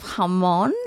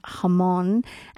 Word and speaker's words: jamón: And it's not jamón. It's spelled jamón with a J, jamón. But it's jamón.